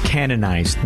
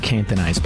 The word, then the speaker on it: canonized: I pronounced canonized wrong. It's canonized, not cantonized.